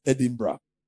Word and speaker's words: Edinburgh: So it's, it's wrong to say Edinburgh. Oh. It's not Edinburgh. Oh. It's Edinburgh.